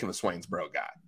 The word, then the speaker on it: Swainsboro: You don't really pronounce that O in Swainsboro. I don't even think you pronounce the final letter. It's just Swainsboro.